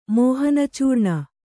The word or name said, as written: ಮೋಹನಚೂರ್ಣ